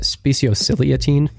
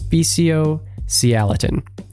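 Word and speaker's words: speciociliatine: I think I called it speciosiliatine. It's speciosialatin.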